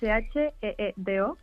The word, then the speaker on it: Shido: Pues mira, se escribe Shido, que es Sheedo, el nombre es un poco complicado para españoles, pero bueno, es que tenemos también presencia internacional.